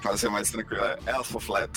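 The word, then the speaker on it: Æthelflæd: Que no caso, escreve-se Alteflaed, né? Mas é Alteflaed.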